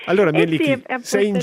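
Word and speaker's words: Mielikki: Miellicchi, il nome della dea finlandese, che bellezza. – Sì, sì, sì, l'avete pronunciato perfetto.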